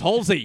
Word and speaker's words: Halsey: It's like H-A-L-S. – Like palsy. – I can't remember how it's spelled, but it's pronounced Halsey.